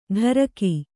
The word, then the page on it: ಢರಕಿ: ḍharaki